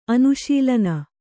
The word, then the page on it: ಅನುಶೀಲನ: anuśilana